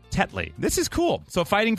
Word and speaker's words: Tetley: Is it Tetley? – Tetley.